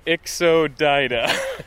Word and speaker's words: Ixodida: So now we're going to make it down to the order Ixodida.